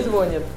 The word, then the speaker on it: звонит: Звонит или звонит? – Звонит. – Звонит на и ударение.